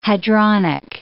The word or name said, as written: hadronic